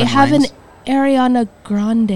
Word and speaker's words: Grande: Ariana Grande is how supposedly you're supposed to pronounce that. Supposedly it's Grande. That's how you're supposed to pronounce it. That's what she said. – Supposed to pronounce the E? – Grande.